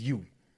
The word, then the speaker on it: Yue: His name is his name is Yu. Like with the U. – Okay. – Yeah, but I mean it's spelled like Y-U-E, but this is Yu. – You know what I'm Y-U-E.